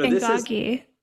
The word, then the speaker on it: Ngagi: and I think I'm going to mispronounce it. So it starts with an I. Ngagi. – No, Ngagi.